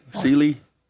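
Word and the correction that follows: céilí: The Sealy? West Side Fall, Sealy? – Kaylee.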